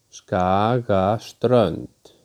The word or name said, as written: Skagaströnd